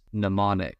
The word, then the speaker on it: mnemonic: I think we maybe have some silent letters on the front of this one. Mnemonic.